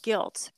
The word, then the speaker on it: gilt: So my new book is called Guilt, but it's spelled G-I-L-T. It's a homonym. So guilt, G-I-L-T, is something that's covered in like a superficial layer of gold.